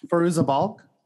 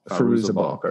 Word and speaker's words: Fairuza Balk: Fairuza Balk. – Fairuza Balk.